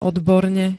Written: odborne

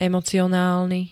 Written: emocionálny

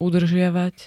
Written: udržiavať

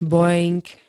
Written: boeing

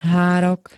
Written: hárok

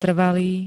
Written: trvalý

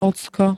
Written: ocko

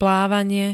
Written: plávanie

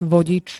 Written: vodič

